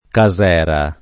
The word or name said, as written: casera